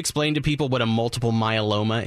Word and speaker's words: multiple myeloma: He was a frail 84-year-old man with, and correct me if I say this wrong, but multiple myeloma and Parkinson's disease. Right? – Oh, you got it.